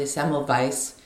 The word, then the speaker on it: Semmelweis: Semmelweis reflex. I should probably say it properly, Semmelweis.